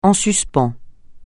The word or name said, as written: en suspens